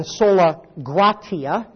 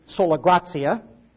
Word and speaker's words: sola gratia: I'll tell you how to pronounce it. It's pronounced in Latin, sola gratia. Or if you're an Italian today, you might say sola grazia.